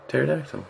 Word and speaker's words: Pterodactyl: Pterodactyl. – Pterodactyl. – Pteri. – The P is silent.